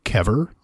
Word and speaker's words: Kever: They are an Israeli band called Kever. I believe I'm pronouncing that right. It's spelled K-E-V-E-R.